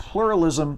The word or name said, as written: pluralism